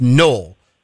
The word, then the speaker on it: Noel: A lot of people don't know this, but there is a town in southwest Missouri called, it's pronounced Noel, but it's spelled yes. N-O-E-L.